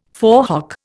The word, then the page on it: forehock: Indian